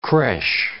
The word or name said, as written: creche